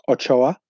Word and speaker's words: Ochoa: Ochoa.